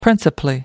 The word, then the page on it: principally: American English